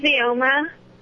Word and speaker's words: Vyoma: Hi, my name's Vioma. – How do you spell that? – V-Y-O-M-A.